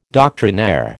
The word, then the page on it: doctrinaire: American English